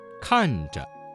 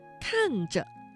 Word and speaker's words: 看着: 看着，看着。 – 这个词当中的遮字读成了轻声，我们一起来造句。